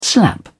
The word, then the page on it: slap: British English